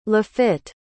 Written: Lafitte

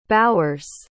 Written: bowers

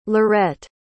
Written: lorette

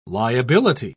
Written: liability

